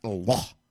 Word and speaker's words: Allah: Because it is always pronounced, properly pronounced as Allah. That's the correct pronunciation. Ayaw gin mo pa yung alam. So na, alauna, alas dos, alas tres. Di na mao. That is supposed to be Allah.